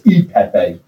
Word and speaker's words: cacio e pepe: Cacio Pepe. And everybody, though, in America pronounces it a little bit different. It's, you know, Cacio Pepe, Cacio Pepe. It's E Pepe because it means cheese and pepper.